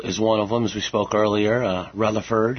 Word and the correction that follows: Rutherfordton: Did I say that right, Tim? – Rutherfordton.